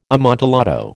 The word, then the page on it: amontillado: American English